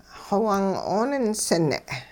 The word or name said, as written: xowung-'ooninsing'